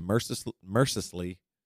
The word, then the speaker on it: mercilessly: While the good children get gifts from St. Nicholas, Krampus is given leave to mercilessly. How do you say that word? – Mercilessly. – Mercilessly. – Yeah. – Mercilessly. Oh, so it's like MRSA and then the name Leslie.